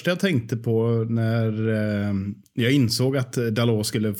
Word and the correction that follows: Dalot: få chansen, Dalot sa jag tidigare, jag ber om ursäkt för uttalet, Dalot såklart, silent T där.